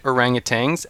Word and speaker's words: orangutan: It survived being handled by the trained orangutans at Delta Airline employees as baggage handlers, and it's still hanging tough. What's that, Trev? – So that's how you pronounce that. – Orangutan.